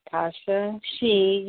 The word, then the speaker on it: Tasha XI: Yeah, so my name is Tasha XI. You can find me on Facebook at Tasha XI, which is spelled T-A-S-H-A and X-I.